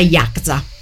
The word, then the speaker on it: Yakuza: La Yakza è la Yakuza che noi intendiamo, ma è pronunciata in maniera distorta. La pronuncia giusta è Yakza, quindi quella setta giapponese che si occupa della malavita.